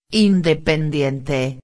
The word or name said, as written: independiente